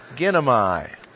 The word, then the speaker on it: ginomai: It's the word ginomai. For transliteration, G-I-N-O-M-A-I.